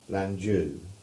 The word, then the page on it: Lanjew: English